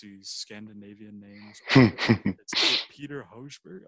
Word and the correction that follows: Højbjerg: It's Højbjerg. – Højbjerg. – That's how you pronounce it.